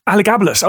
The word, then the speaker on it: Elagabalus: Elagabalus.